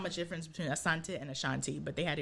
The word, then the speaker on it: Asante: Coco Asante. – Asante. Okay. – Mm-hmm. Mm-hmm. – Because, girl, I don't know what I be saying. I say it the black way. Asante, like Ashanti.